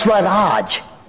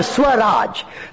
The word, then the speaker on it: Swaraj: The second principle Mahatma Gandhi pronounced and propagated was Swaraj. A Hindi word, Swaraj.